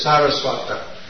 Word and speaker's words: Saraswata: The word is S-A-R-A. The actual word is Saraswata. It's derived from Saraswati. Bhaktisiddhanta Saraswati. But this word is Sara with a long A, Saraswata, which means Prabhupada, the disciple of, offspring of, Bhaktisiddhanta Saraswati.